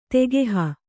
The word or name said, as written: ತಗಹು